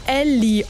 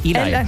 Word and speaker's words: Ely: Also E-L-Y, okay. Weißt du, wie ich das am Anfang ausgesprochen habe? – Nee. – Ellie Oaks. – Ah, nee, Eli. – Ja, Eli, als ob das so... Ja, nee, ach, Eli. Ja, wo soll ich das denn wissen? Das E-L-Y, das ist doch im Amerikanischen da keiner Eli.